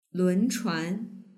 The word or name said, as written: lunchuan